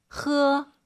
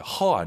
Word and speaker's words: 喝: 喝. – 喝. 약간 저 어때요 여러분? 목소리 좀 긁었죠? 喝. 喝. 이런 느낌으로 하셔도 좋을 것 같아요. 허 아니고 흐도 아니고 喝.